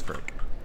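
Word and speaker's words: Frik: Boba Freak? Frick, not Freak. – Babu? – It's Frick. – The tiny little thing. – The tiny. – Oh, yeah, Boba Frick.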